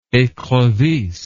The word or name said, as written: ecrevisse